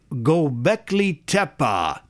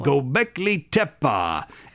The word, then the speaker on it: Göbekli Tepe: Göbekli Tepe. Did I say that right? – Yes, actually. – Göbekli Tepe.